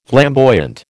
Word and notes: flamboyant: American English

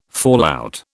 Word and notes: fallout: British English

